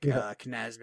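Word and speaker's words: Knies: I can never say it right, but I think it's like a silent K. The Toronto Maple Leafs draft pick, Knaz maybe, I think.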